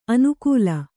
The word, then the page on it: ಅನುಕೂಲ: anukūla